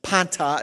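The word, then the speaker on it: Panta (πάντα): Panta is how you pronounce the Greek, all things, obviously.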